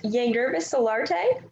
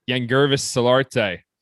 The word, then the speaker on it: Yangervis Solarte: I believe his name is pronounced Yangervis Solarte, second base. – Yangervis Solarte. – Yes.